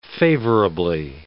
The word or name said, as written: favorably